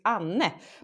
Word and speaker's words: Anne: Oh, and the first thing is, in English, my first name probably sounds strange because it's Anne, but in English it probably will be Annie. – Annie. Okay. – But you pronounce the E, and that is a bit difficult when you speak English, of course.